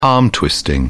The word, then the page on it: arm-twisting: British English